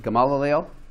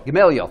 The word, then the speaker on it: Gamaliel: Gamaliel? – Gamaliel. – Gamaliel, thank you.